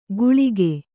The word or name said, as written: ಗುಳಿಗೆ